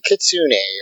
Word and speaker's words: kitsune: I forgot to mention, I am Trish is the yellow ranger, and she has the kitsune. I'm sorry, I don't know how to say it. – It's mythical kitsune. Fox. – Kitsune, thank you.